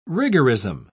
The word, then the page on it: rigorism: British English